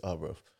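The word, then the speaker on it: Arbroath: Is it Arbrough or Airbrough? How do you pronounce it? It's Arbrough.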